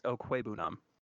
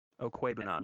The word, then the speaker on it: Okwuegbunam: It's Okwuegbunam. – Okwuegbunam? – Okwuegbunam. You don't pronounce the G.